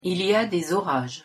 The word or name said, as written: il y a des orages